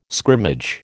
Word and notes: scrimmage: American English

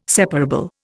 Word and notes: separable: Indian

